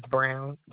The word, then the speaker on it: Brown: It's spelled D-U-A-N-A. My last name is Brown, B-R-O-W-N.